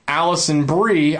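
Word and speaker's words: Alison Brie: He wants to know, who is hotter, Allison Brie, I think that's how you pronounce her name. – Yeah, it is.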